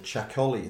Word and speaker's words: Txakoli: There's a great wine in the Basque country called Chacoli. It's spelled T-X-A-K-O-L-I.